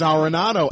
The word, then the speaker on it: Arenado: I can't even say his name. – It's Arenado.